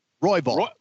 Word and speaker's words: Royval: Royval.